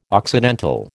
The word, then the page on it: occidental: American English